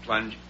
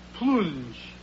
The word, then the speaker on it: Plunge: P-L-U-N-G-E, Plunge? – Plunge.